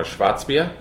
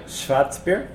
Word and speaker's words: Schwarzbier: It's called a Schwarzbier. – Schwarzbier. – There you go.